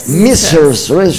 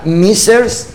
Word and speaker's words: Mrs.: ¿Cómo se pronuncia? – Mrs. Mi inglés de alcantarilla, disculpe. ¿Qué representa para usted? O sea, ¿qué debe hacer una Mrs. Folklore Universe? Ah, lo dije bien. – Ajá. Sí, ay, lo dijo muy bien.